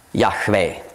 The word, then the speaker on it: Yahweh: Diese 4 Buchstaben im Hebräischen, Yud, Hei, Vav, Hei. Ausgesprochen, Yahweh, der Ewigseiende.